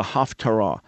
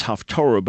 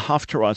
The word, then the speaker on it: Haftarah: So in the synagogue every week, there's a Torah portion read, and then there's a supplemental portion read called the Haftarah, which colloquially gets pronounced as Haftorah, but it's the Haftarah, it's the supplemental portion.